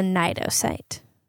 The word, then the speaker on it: cnidocyte: So if we come into contact with jellyfish's tentacles, they have a cell on their tentacles called a cnidocyte. It's spelled with a silent C at the beginning, which I don't understand. I don't understand much about biological naming systems. But that was how I learned that it was pronounced.